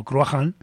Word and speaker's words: Cruachan: y acabó formando pues este grupo que se ve pronunciado así como Cruachan, y que corresponde pues a un héroe de la mitología irlandesa, que sale en el Ebor Gábala.